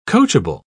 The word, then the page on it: coachable: British English